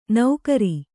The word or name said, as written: ನೌಕರಿ